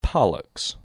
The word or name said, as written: Pollux